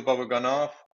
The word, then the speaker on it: Babaganov: My guest today is Rachel Babaganov.